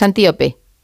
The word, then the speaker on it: Antíope: ahondas mucho en la relación de Teseo con Antílope. Pero lo he dicho mal el nombre, es Antíope. – Antíope, ¿verdad? – Antíope, o sea, Antíope, no, que la llama yo como un animal y no puede ser, Antíope.